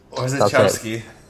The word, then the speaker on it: Orzechowski: lettered by Tom Orzechowski. I can't say it properly. – Orzechowski. – Yeah, that's it.